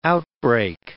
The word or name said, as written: outbreak